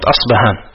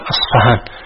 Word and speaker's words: أصفهان: أصفهان. والسبب في هذا أن أصلها أصفهان، والعرب لا تنطق بهذا الحرف، فلهذا تعرب مرة بالفاء وتعرب مرة بالباء.